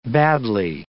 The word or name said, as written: badly